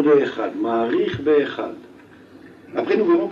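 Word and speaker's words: Echad (אחד): On devra allonger dans la prononciation dans le mot de Echad, mais allonger en particulier le mot de Dalet.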